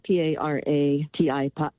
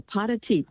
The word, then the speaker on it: para ti: So, for example, when I was studying Spanish, there's an expression for you, and it's spelled P-A-R-A-T-I. And so most English speakers will say para-ti. My Spanish teacher said, wait a minute, go in the kitchen, put on some water, and make a pot of tea. I go, pot of tea. When I started saying that, then I knew the pronunciation correctly in Spanish.